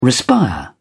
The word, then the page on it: respire: British English